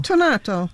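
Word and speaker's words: tonnato: Tonato.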